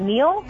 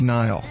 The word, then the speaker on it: Niall: Is it Neil? – I thought it was Niall. – Niall. – I thought it was Niall. – It's Niall. Even I know that. – It's probably Niall.